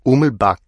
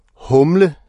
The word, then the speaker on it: Humlebæk: Humlebæk. Og de morede sig sammen over Hermins udtale af Ummelbak. Humle, sagde Claudi. Humle, ligesom det øl, du ikke sælger.